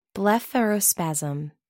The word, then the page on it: blepharospasm: American English